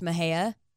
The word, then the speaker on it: Mexia: Like Mejia is spelled Mexia, but it's pronounced Mejia.